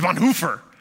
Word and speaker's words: Bonhoeffer: And then we began to talk about Dietrich Bonhoeffer, and he'd say, oh, you Americans, you don't know how to pronounce his name. It's Bonhoeffer, Bonhoeffer. And we'd say, well, how do you say it? And he'd say, it's Bonhoeffer. Sounds like a mouthful of a BMW.